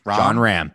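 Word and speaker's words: Jon Rahm: What about, I don't know how to say his last name, John Rahm? – John Rahm.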